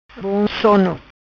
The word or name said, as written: Bom Sono